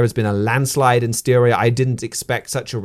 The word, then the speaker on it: Styria: And the Austrian public had a chance to have their say on that, where they had a state election in Styria. Am I saying that right? Close enough? – I think so. – Styria in late November.